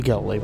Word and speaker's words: Galilei: I usually say Galilei myself.